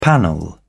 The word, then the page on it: panel: British English